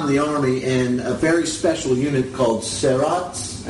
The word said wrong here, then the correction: Sayeret Matkal: Sayeret Matkal.